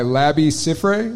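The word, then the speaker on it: Labi Siffre: It's called I Got The, and it's by Labby Siffre.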